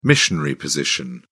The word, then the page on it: missionary position: British English